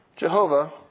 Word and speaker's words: Yahweh: Common pronunciations are Jehovah versus Yahweh.